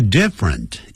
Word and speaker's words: different: 呈现出怎么样呢 different style 这个 different 也是两个音节不要念成 different different different 好特别要这样记住